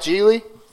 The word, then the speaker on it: Gigli: Gigli. – Ah. – The second nothing. – G is silent.